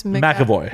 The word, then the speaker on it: McAvoy: James McAvoy.